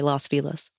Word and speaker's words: Los Feliz: Yeah, it's spelled like Feliz Navidad, but they say it Feliz. Now, okay. They don't say it like that. They say Los Feliz. – Okay. – That's how the locals pronounce it, from what I gathered.